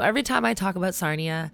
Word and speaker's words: Sarnia: Is it Sarnia? Is that Sarnia, yes. – It rhymes with Narnia.